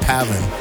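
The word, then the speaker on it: Pav4n: This is the latest single under his new alias, Pav Foreign, but it's pronounced Pavin. Spelled Pav, number 4N.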